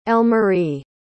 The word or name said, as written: Elmarie